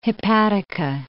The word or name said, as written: hepatica